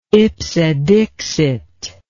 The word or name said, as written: ipse dixit